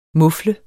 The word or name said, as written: MUFL